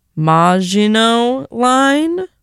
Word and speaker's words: Maginot Line: Before that, okay, I mispronounced it terribly before. I looked it up though. Maginot Line by Sunken Plains.